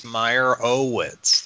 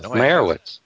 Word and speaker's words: Meyerowitz: It's Meyerowitz. There's an O in there. – That's what I said. Meyerowitz.